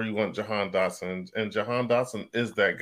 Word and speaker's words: Jahan Dotson: He's going to have Jahan Dotson.